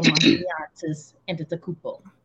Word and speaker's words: Giannis Antetokounmpo: 14 teams passed on Giannis Antetokounmpo. Did I say that right? Sounds good, right? – That was pretty good.